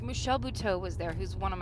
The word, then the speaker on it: Buteau: Buteau. – Okay. – B-U-T-E-A-U. Yeah. – Oh, okay. – Kind of Frenchy a little bit.